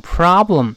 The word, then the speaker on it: problem: 那 the problem 就是毛病、问题、困难，在这儿那个字母 o 要发啊，读成 problem。但是 pro 最重最重，不要读 problem，不能读 problem。 pro 最重，后面像下滑梯一样就滑下来了。